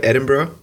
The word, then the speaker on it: Edinburgh: Did I say that right? Edinburgh? – Yep.